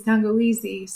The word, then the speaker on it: tangawizi: So ginger is tangawizi in Swahili. It's like the words ginger. How you say it in Swahili is tangawizi.